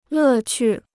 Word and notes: 乐趣: lè qù

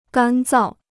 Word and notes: 干燥: gān zào

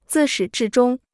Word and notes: 自始至终: zì shǐ zhì zhōng

